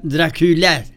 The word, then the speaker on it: Dracula: Drácula en inglés se pronuncia Dracula.